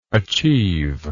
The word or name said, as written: achiever